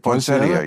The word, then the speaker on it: Poinsettia: Poinsettia. How do you say it? – Poinsettia, yeah.